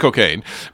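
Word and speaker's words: Cockaigne: Yeah, it is pronounced Cocaine, but I'm just to emphasize, there's a G.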